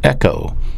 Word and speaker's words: echo: That is a verb in the Greek New Testament pronounced echo, E-C-H-O.